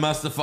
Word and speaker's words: Mustafa: So next up we have Mustafa Ali versus Austin Theory. – Mustafa? Yeah, that's how you say it. – From The Lion King? – Yes. – That's Mufasa. – No, that's Mufasa.